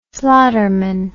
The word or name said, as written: slaughterman